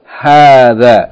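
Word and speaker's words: hadha (هذا): And hadhihi is pronounced as though the first syllable is long, hadhihi, in the same way as hadha is pronounced hadha, as though there's an aleph, even though it is not written.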